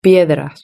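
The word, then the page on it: piedras: Spanish (Spain)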